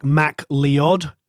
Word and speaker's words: MacLeod: In America, people can't pronounce his name. So if the Americans are listening, it's like Mac Leod.